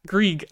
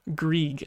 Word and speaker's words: Greig: But yeah, Ridly Greig's debut, or Greig, I said it right away. Oh my god. Ridly Greig's debut. I don't know why, but I always mispronounce this dude's name. I always read it in my head as Greig, and then that's how I've been saying it this whole time, and then it's Greig, and so I need to get that straight.